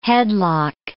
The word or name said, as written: headlock